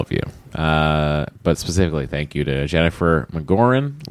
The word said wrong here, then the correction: McGrogan: Lisa Gold. – McGrogan. – McGrogan. Jennifer McGrogan. I did mess that up.